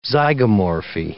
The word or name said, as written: zygomorphy